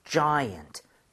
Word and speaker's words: giant: So, giant. Giant. Okay? I의 강세를 찍고요. – 네. – 엄청 큰이라는 형용사가 되겠습니다. 요거 발음 한번 해볼게요, 여러분들. Okay? I의 강세 주고 두 번.